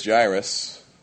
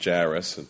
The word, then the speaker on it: Jairus: His name is Jairus. That's how we pronounce it, so I'll call him that, Jairus.